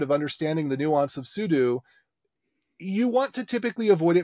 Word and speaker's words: sudo: But it's super user do, sudo.